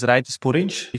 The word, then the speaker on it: Raitis Purins: So my name is Raitis Purins.